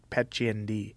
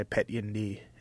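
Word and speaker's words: Petchyindee: It would be pronounced in English like Pet Chien Di, but in Thai, Pet Yin Di.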